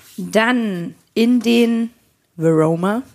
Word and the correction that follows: Varoma: Hm, Varoma. – Varoma. – Ja, das äh, wird leider nicht ganz so cool ausgesprochen. Veroma klingt cool.